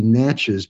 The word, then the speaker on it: Natchez: or you pronounce it in English, it would be Natchez, but their name for their own nation is the Na'chi Nation.